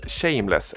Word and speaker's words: SHMLSS: Eh, Train Ride to the Middle East med eh, den holländska duon, eh, jag tror det uttalas Shameless, S-H-M-L-S-S, utgiven på Eskimo Recordings, det belgiska storlejmen får man säga, som har släppt mycket mm.